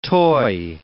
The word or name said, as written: toy